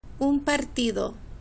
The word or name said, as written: un partido